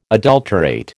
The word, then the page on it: adulterate: American English